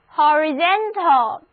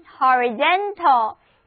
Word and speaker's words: horizontal: 现在我们在 horizon 后面加上 tal 的形容词后缀， horizon 的形容词就出现了， horizontal，重音放在第三个音节上，两个 o 字母都发 o 的音， a 字母不发音。 read after me，跟我读这个单词，就是 horizontal， horizontal 意思是地平线的，水平的。